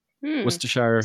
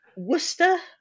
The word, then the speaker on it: Worcestershire: Well, you know, Worcester, I say, which is probably wrong. Worcester sauce. – Oh, you dropped the whole, dropped the whole shire off it. – Cut out all the syllables.